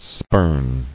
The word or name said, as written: Spurned